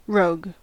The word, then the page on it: rogue: American English